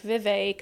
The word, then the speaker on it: Vivek: Crafty Grandma Crispy, Vivek says it's pronounced like cake. Vivek.